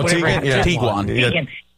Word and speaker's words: Tiguan: Tiguan? – Oh, Tiguan.